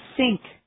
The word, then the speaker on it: think: I think. Think. I think. – به تلفظ دقت کنید. باید نکته‌بونی بگید. علاوه بر اون بعد از صدای تلفظش ای نیست. یه صدای بین ای و ای.